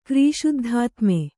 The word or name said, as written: ಕ್ರೀಶುದ್ಧಾತ್ಮೆ